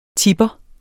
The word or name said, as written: TIPPEREN